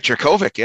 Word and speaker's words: Jurkovec: You know, so we mentioned that they've struggled without Phil Jurkovec uh, here. I'm almost positive I'm pronouncing that wrong. Um, Jurkovec. – No, you're doing it right. You're oh, doing yeah. it right, Jurkovec.